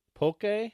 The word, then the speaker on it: poke: Poke? – Yeah, poke. – This place is called Ocean. Poke is spelled P-O-K-E.